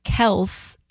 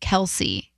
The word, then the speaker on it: Kelce: And said that we should be saying Kelce instead of Kelce.